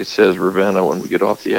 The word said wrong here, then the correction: Ravenna: Yeah, it's pronounced Ravina. Go ahead. – Ravina. Mm. I know. I still don't get that right.